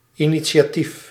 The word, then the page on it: initiatief: IPA: /inisjaːtif/